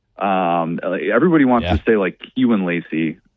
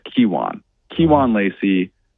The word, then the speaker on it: Kiwan Lacy: And then how about, let's go with, Kiwan Lacy, because everybody wants to say Kiwan Lacy, and it's Kiwan Lacy. Emphasis on the key part of it.